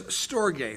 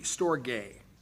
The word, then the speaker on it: storge: The third is storge, hard G, storge.